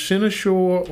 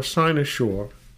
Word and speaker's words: cynosure: Our word of the day can be pronounced one of two ways, cynosure or cynosure. C-Y-N-O-S-U-R-E.